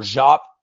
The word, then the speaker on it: Diop: I honestly feel like Diop or Jop, as some people have pronounced his name one way or the other, is going to be a draft and stash type player.